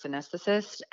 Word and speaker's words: anesthetist: And his wife is a nurse anesthetist. And mm-hmm. Is that how you say it? – Yeah.